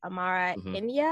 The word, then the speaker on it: Amara Enyia: You worked with a 2019 Chicago mayoral candidate, Amara India, I believe I pronounced that right. – That's correct.